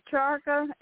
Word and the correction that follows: Chakra: Is it my saying it right? C-H-A-R-K-A? – Chakra.